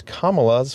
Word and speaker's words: Kamala: Also, Trump seems to be unwilling or unable to correctly pronounce Kamala's first name. Think of the punctuation mark, comma, and then say la, Kamala.